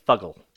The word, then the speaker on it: Fuggle: And then 1.25 ounces of Fugle hops. – Fuggle? – Yes. – Fugle? I'm British. I'm going to correct you on your pronunciation, but yeah, Fuggle.